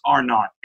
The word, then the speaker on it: R naught: It's called R0 or R naught. It's pronounced R naught.